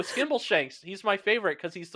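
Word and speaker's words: Skimbleshanks: Skimble Shanks. Okay, you did say it right.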